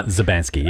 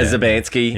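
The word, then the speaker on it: Szubanski: But it was a great night because Magda Zabanski, I believe I'm saying her name right. – Zabanski, yeah. – Zabanski.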